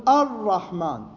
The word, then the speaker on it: الرحمن: مثلا كلمة الرحمن اللام هنا لام شمسية فأقول الرحمن أر من الألف للراء اللام ما تلفظ